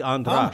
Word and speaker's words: Andras: My name is Andras, and nobody pronounces it correctly. So, right. – I'm used to it. – German. – Hungarian. It's actually Andras.